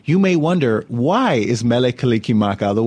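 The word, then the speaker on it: Mele Kalikimaka: The Hawaiian language has no sound for R or S, and every syllable has to end with a vowel sound. So Merry becomes Mele, and Christmas becomes Kaliki Maka.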